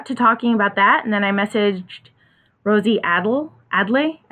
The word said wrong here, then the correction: Adel: I don't know. I can never say it right. But Adel. Rosie Adel. – Adel. Thank you.